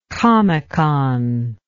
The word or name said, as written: comecon